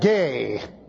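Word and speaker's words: gay (γῆ): Looks like that gay. It really is just simply G-E.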